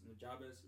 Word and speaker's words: Nujabes: The intro to that guy to, to their cartoon is by Nujab Nujab. I'm probably butchering the name mm-hmm. Nujabis Nujabes, mm-hmm.